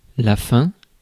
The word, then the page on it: hunger: /ˈhʌŋ.ɡɚ/; /ˈhʌŋ.ɡə/